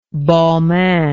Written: balmain

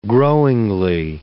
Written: growingly

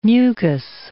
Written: mucous

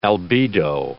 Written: albedo

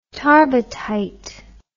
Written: tarbuttite

